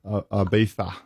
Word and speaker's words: Ibiza: Say it right. – Ibiza. Yeah.